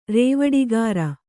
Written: ರೇವಡಿಗಾರ

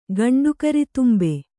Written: ಗಂಡುಕರಿತುಂಬೆ

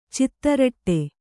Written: ಚಿತ್ತರಟೆ

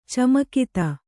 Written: ಚಮಕಿತ

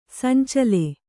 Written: ಸಂಚಳ

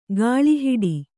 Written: ಗಾಳಿಹಿಡಿ

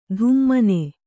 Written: ಧುಮ್ಮನೆ

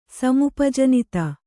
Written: ಸಮುಪಜನಿತ

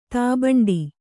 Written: ತಾಬಂಡಿ

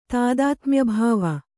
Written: ತಾದಾತ್ಮ್ಯಭಾವ

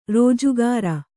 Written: ರಾಜಗೀರೆ

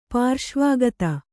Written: ಪಾರ್ಶ್ವಗತ

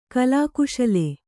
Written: ಕಲಾಕುಶಲೆ

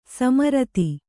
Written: ಸಮರ್ಥ

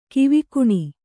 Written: ಗಾವಗಾನೆ

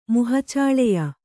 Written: ಮುಹಚಾಳೆಯ

